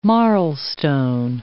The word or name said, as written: marlstone